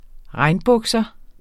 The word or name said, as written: regnbukser